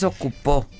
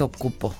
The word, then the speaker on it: ocupo: No es ocupó, es ocupo. Y le pusimos acento en la O.